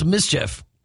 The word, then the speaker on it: MSCHF: And she's teamed up with this company called, well, it's spelled MSCHF. So it's Mischief.